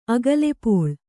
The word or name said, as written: ಅಗಲೆಪೋಳ್